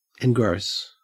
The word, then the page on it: engross: Australian English